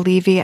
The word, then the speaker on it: Levy: Paul Levy? I never know how to pronounce his last name. – I call him Levy.